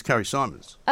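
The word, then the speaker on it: Carrie Symonds: I sometimes call her Carrie Simmons, but actually it's Carrie Symons.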